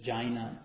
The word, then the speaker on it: Jain: So it's actually Jain. Jain. It's actually the A and the I pronounced independently. Not Jain, but Jain.